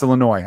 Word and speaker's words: Illinois: And I was like, he pronounces the S. He's only read of Illinois. He's never heard of it.